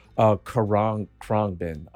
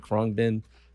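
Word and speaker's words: Khruangbin: But I'm actually going to give it to Karang Krongbin. I cannot pronounce the name. It's K-H-R-U-A-N-G-B-I-N, Krongbin.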